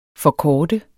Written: forkorte

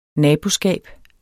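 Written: naboovervågning

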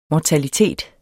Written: mortalitet

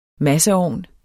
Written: masseovn

